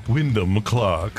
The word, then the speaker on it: Wyndham: Say his name the right way. – Wyndham Clark. – Thank you. – That's the right way to say it.